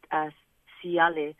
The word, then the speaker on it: taiale: And then you have Niue who have T, but then if it's follows by a certain vowel, it becomes an S. So we learnt, and an example would be the word siale, which is a flower, and it's spelled T-A-I-A-L-E. But because there's a vowel I after it, the T turns into an S.